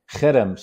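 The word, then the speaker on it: herem: Cherem. So it's H-E-R-E-M, but it's like a hard guttural. Ha. – Chayrim.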